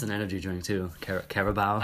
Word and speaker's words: Carabao: But it's pronounced in a way that makes it sound like you're missaying the word Caribou. Carabao.